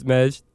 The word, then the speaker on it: Majd: Did I say it right? I feel, it's been so long. I just call you Mark. – Yeah, it's been a minute. My name is Majd.